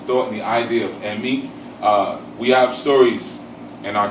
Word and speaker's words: Emi: In Yoruba, E's are pronounced like E, like you would say for egg. It's E. Okay? And I's are pronounced like E, like in feet. All right? So Emi is spelled E-M-I. Emi.